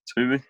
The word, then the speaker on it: Twomey: Twomey. – Liam Twomey from The Athletic. – If in doubt, yeah. Just think Chuckle Brothers to me to you.